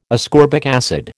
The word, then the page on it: ascorbic acid: American English